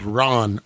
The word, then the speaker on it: Ran: It's called Ron, R-A-N, Ron. And then that's our cinema from Japan. And from Hong Kong, we'll be watching the early 80s classic Police Story. Police Story. – Ooh. How do you spell that last one? – Police Story. – No, Ron? – Ron. R-A-N as in nickel. – Ran. – It's Ran, but in Japanese.